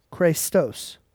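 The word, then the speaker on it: Chrestos: The word is Christos. C-H-R-E with the accent. S-T-O-S. Pronounced Christos.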